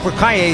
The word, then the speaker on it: L'Écuyer: L'écuyer. – Did I pronounce that wrong? – You know, you got it right. You got it good.